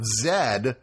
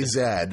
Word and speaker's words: Zed: Like the movie we're talking about is The Lost City of Zed. – I've been mistakenly calling it Z, but this is a British film, and the British say Zed.